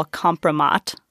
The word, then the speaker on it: kompromat: kompromat, yes. – I guess, if I'm pronouncing that correctly. – Yes.